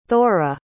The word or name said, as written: thora